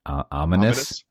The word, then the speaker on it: ominous: Omnia, how do you say that? Ominous? – Ominous. – Ominous. – Ominous. – There you go.